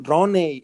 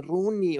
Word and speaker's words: Rooney: no se pronuncia eh, eh, Roney, es, Rooney,